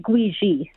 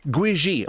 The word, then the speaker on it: Gwizhii: Did I say that correctly? Gwisley? – It's actually Gwiji. – Gwiji. Okay, I'm sorry. I'm sorry. – It's okay. It's spelled G-W-I-Z-H-I-I.